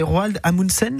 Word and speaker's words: Roald Amundsen: J'essaye de prononcer Roald Amundsen.